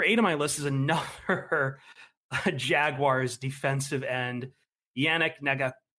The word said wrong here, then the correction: Ngakoue: I can't say his name, man. Yannick Ngakoue? Yeah.